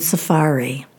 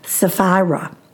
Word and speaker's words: Sapphira: I'm sorry, I said that wrong. I think I'm saying all of the technological words I'm getting? Sephora the store and Safari the server and her name, which is Sapphira.